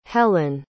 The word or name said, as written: helyn